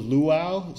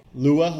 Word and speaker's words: Lua: But some interesting changes that I noticed is that, so it uses the Luau scripting language, which is a really lightweight language that's ideal for scripting. – Is that how you pronounce it? – I think it's Lua. – Lua, let's say Lua.